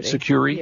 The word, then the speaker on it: Sucuri: You found an article about Sucuri, I guess it's pronounced. – Sucuri, yeah.